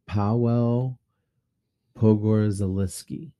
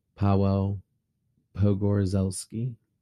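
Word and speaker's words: Paweł Pogorzelski: But his name is Pawel Pogorzelski. – Hey, sounds right to me. – Okay. I hope I did justice, Pawel Pogorzelski.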